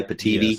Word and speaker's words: Pitivi: So you've got Kaden Live, you've got OpenShot, one called Pativi, if I'm pronouncing that, P-I-T-I-V-I, Pativi, yes.